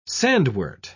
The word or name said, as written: sandwort